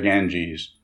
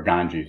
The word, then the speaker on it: Ganges: There are the 10,000 things, the sands of the Ganges, or Ganges, I should say. Got to learn to pronounce that right.